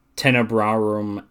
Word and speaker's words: Tenebrarum: There's one, how do you pronounce her name again? Is it Tenebrarum? – Tenebrarum and Lacrimarum.